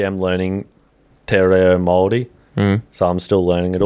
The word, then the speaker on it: Māori: No, it's not Māori. – I don't fucking... – No, it's Māori. I can't roll my R's properly. – Māori.